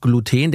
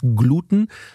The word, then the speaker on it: Gluten: Also Gluten wäre die deutsche Aussprache und ich bin sehr angetan, wenn Linguisten oder Germanisten das nochmal ansonsten revidieren würden. Das ist mir nur so bekannt. Gluten ist deutsch und Gluten ist die englische Aussprache oder angloamerikanische Aussprache.